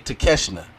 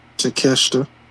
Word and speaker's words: Takeshita: Six-man tag team match, Bullet Club Gold, Jay White, Juice Robinson, with Kenosuke Takeshita. Did I say it right? – Takeshita. – Okay. A little off, but I was proud of myself.